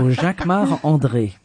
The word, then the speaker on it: Jacquemart-André: Jacquemart-André. Jacques. Or no, you say it like André. A Frenchman. – Jacques, of course, because I'm French. So Jacquemart André.